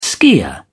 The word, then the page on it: skier: British English